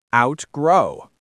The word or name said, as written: outgrow